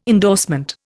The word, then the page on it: endorsement: Indian